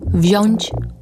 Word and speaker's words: wziąć: Inaczej niż na przykład w słowie siąść, gdzie słyszymy długie, długie ść, ść, siąść. Ale przecież branie nie ma z siedzeniem nic wspólnego. Dlatego też inaczej wymawiamy te słowa. Mówimy siąść długo, wziąć krótko.